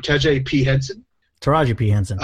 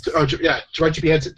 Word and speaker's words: Taraji P. Henson: So I went with, I'm probably going to butcher her name, Taraji P. Henson, yes. – Taraji P. Henson.